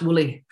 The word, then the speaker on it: Willie: It's pronounced Woolly.